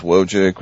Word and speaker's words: Wojcik: As mentioned, you can follow us on Twitter at my name is Wojcik, which is spelled W-O-J-C-I-K.